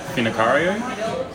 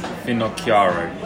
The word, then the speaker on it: Finocchiaro: Well, I pronounce it, or the family pronounces it, Finocchiaro. But Finocchiaro. – Technically, yeah. It's Finocchiaro. – Finocchiaro.